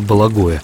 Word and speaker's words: Бологое: Произносится это, склоняется ли это название или нет? – Ну, обычно произносится Бологое, хотя в просторечии Бологом тоже встречается.